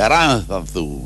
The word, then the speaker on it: Aranzazú: De Aranzazú, ¿está bien pronunciado? – Así es.